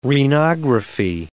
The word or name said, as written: renography